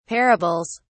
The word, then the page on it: parables: British English